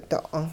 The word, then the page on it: do'oon: hupa